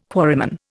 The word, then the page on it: quarryman: Indian